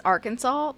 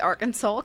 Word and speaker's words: Arkansas: It's Arkansas, but whenever I see Arkansas, because the way it's spelled, I'm like, Arkansas.